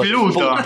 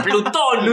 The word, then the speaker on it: Plutón: Quiero ver cómo pronuncia esto. – Dale. – Sí. Estoy muy atento. – La sonda New Horizons se acerca a Pluto. – ¡Qué hijo! – ¡Pluto! – ¡Plutón! ¡Pluto! Eso quería saber.